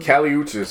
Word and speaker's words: Kali Uchis: Jacquees, Georgia Smith, and how do you say her name? – Kali Uchis.